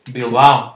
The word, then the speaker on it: Bilbao: Bilbao. – Bilbao.